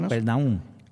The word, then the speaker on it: Capernaum: En mi escritura dice, en mi libro, en mi Biblia, que es una traducción antigua, dice, y entraron en Capernaum, ¿cómo se dice la palabra? – Capernaum.